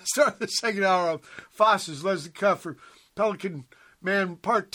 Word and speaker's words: fauces: You gave me this, how do you pronounce this? Fauces? – Fauces. – As opposed to feces.